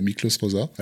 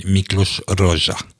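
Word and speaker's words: Miklós Rózsa: Miklos Rosa. Alors je le prononce mal, je sais. – Voilà, en, Hongrie on dit Miklos Roja Pas vraiment nécessaire de le présenter.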